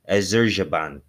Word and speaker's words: Azerbaijan: Hey, Google, how do you pronounce Azerbaijan? – That's pronounced Azerbaijan. – That ain't going to help, you know. Hey, Google, how do you pronounce Azerbaijan? – That's pronounced Azerbaijan.